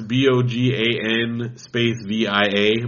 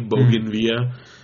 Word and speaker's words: Bogan Via: They're called Bougainvillea, but it's spelled like not like the flower. It's Bogan Via, Bougainvillea.